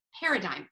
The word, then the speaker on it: paradigm: It's a big word, P-A-R-A-D-I-G, silent G-M, paradigm.